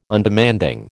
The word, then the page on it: Undemanding: American English